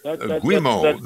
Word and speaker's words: Guimo: Guimo, the novel that he published in 1915. – Guimo is an abbreviation for Guillermo, which is the Spanish word name for William.